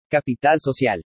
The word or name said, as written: CAPITAL SOCIAL